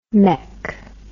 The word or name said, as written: meck